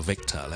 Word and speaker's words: Victor: 最近咧， 有一个朋友咧，就叫 Victor 咧，就亲自开口咧，就要求 咧讲讲 Victor 呢个字个发音，呢个名字嘅发音，咁啊，希望咧就大家会读好少少。 Victor 呢个字，Victor，有两个地方要留意嘅。 Number one, it starts with a V sound, V 音开头嘅，所以咧我哋咧系 V 咁嘅音嘅，V 而唔系。